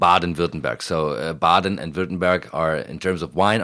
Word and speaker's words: Württemberg: It is Württemberg. It looks like Württemberg when you look at it, but it's a Württemberg. – It's Württemberg, exactly. Very, very well pronounced.